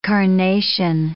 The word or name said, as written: carnation